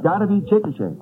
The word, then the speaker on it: Chickasha: C-H-I-C-K-A-S-H-A. I said Chickasha. – Oh, Mr. McNeil. – That is not right, I've no, no. No. But the name is pronounced Chickasha.